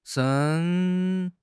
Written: shen1

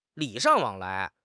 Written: 礼尚往来

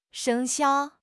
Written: 生肖

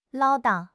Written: 唠叨

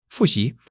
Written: fu4 xi2

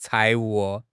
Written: 财务

